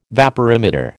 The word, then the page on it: vaporimeter: American English